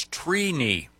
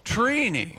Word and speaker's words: Trini: The correct pronunciation is Trini. – Wow. – Trini. – Like T-R-E-E. – Like weenie. – Yeah, So is it phonetically T-R-E-E-K-N-I? – Yeah, it's, phonetically it is T-R-E-E.